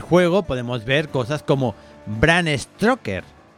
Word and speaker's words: Bram Stoker: Bram, Bram Stoker. Eh, sí, sí, vamos, que la has pronunciado súper bien.